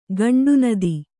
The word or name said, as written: ಗಂಡುನದಿ